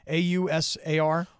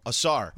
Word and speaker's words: Ausar: Ausar. – Oh, Ausar.